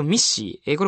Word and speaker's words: MECE: まず最初ミッシー、これは MECE と書いてミッシーなんですけど、本によってはミッシーなんていうふうに言うところもありますけどね、今回の本に即してミッシーと呼びます。